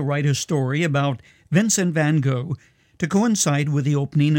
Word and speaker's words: Van Gogh: One of those enigmas is a Dutchman known as Vincent van Gogh, Van Gogh, if you pronounce it like the Dutch would, whose magnificent paintings hang in a museum that bears his name in Amsterdam.